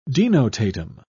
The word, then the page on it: denotatum: British English